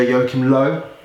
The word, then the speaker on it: Joachim Löw: Their manager, Joachim Löw. – Yeah, good. Good pronunciation.